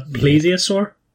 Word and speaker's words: plesiosaur: You're going to have to help me with the pronunciations here, but I think it's an ichthyosaurus, an ichthyosaur and a plesiosaur. Is that correct? Yeah. Spot on.